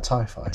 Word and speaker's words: TyFy: How do you pronounce it? Is that TyFy? – TyFy, yeah. – T-Y-F-Y. – T-Y-F-Y.